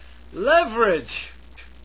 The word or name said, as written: leverage